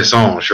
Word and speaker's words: Singes: Le Planet des Singes, right? – Yeah. – Des Singes. I always get corrected. I always pronounce that word wrong with my French friends. – I think it's Singe.